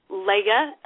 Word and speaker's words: Leah: Leia. – Well, could you say your name again? – Leia, L-E-A-H.